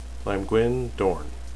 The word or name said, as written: lamgwin